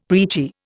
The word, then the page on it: preachy: Indian